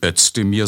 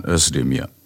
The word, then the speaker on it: Özdemir: Er heißt eben nicht Özdemir, sondern Özdemir. Denn so wird Z im Türkischen innerhalb eines Wortes immer ausgesprochen.